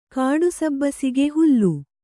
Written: ಕಾಡುಸಬ್ಬಸಿಗೆ ಹುಲ್ಲು

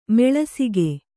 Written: ಮುಳ್ಳುಸೀಗೆ

